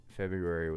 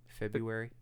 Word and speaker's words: February: February was. Am I saying that wrong? February? Isn't it February? – That's how it's like spelled, but it's February.